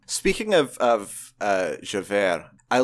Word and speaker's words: Javert: Well, he does it on Javert? – Javert. – Javert? – I feel like you don't pronounce the T in French.